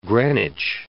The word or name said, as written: Greenwich